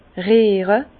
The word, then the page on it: riere: Bas-Rhin; Herrlisheim